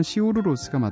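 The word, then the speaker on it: Sigur Rós: 자, 끝곡으로 시교로스의 다우달론이라는 노래 준비했습니다. 정확한 발음은 시우르로스가 맞다고 그러죠.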